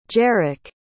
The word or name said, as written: jerrick